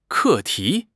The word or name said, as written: 课题